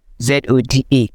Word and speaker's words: Zoda: Pronounce it for us now. – Zoda. Hey, eh? yeah, it sounds like soda. sounds like what? soda. like yes, oh yeah. – like just soda.